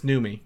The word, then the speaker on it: Noomi: I think it's Naomi Rapace. Maybe. – It's not Naomi. This Noomi. It's definitely.